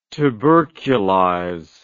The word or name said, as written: tuberculize